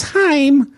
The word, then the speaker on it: Haim: I think it's four sisters, and it looks like it says Haim, but it's pronounced Haim.